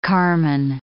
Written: carmine

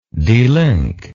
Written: delinkage

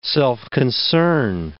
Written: self-concern

